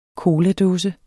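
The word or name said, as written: COLADÅSER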